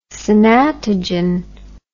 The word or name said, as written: Sanatogen